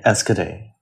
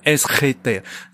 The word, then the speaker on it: Enschedé: 然后，因为他在他的那个那个印刷厂嘛，叫那个什么恩斯赫德、对，我们英文就念 Enschede。但我觉得可能荷兰语是怎么念的来着？ – 荷兰应该是念 Enschede，那个他这个是皇家印刷厂嘛，对吧？